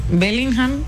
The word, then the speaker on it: Bellingham: Bellingham, ¿sí? – Sí.